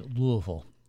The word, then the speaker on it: Louisville: No, you just have to kind of swallow it. It's Louisville. – Louisville.